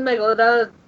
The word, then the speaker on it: Megalodon: Or the Megalodon. – Megalodon, yeah, you said it right.